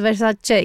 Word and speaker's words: Versace: Εμείς προφανώς είχαμε την Donatella Βερσάτσε, η οποία κρατούσε και ένα τέλειο καρτολίνο που έλεγε Versace, ότι προφέρεται Versace, γιατί οι Αμερικανοί τη λένε ή Versace ή Versace και έκανε πλάκα λοιπόν με αυτό.